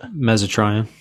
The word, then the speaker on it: mesotrione: mesotrione